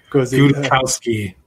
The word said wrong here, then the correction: Guzikowski: Yeah. Let's see, how's it spelled? Okay. It's a Guzikowski. Sorry.